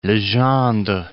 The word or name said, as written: Legendre